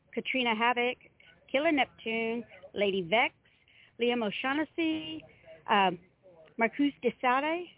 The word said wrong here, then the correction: Marquis de Sade: is that how you pronounce that one? – Marquis de Sade.